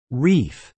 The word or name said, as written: reef